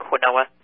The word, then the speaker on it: quinoa: But the good carbs, and they're more expensive, so they're not in as many foods, would be quinoa, which is spelled Q-U-I-N-O-A. It looks like quinoa. It's pronounced quinoa.